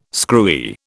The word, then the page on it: screwy: British English